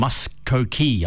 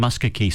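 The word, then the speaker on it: Muskogee: Oki from Muskokie. I'm sure I mispronounced that first. So it's Oki from Muskokie.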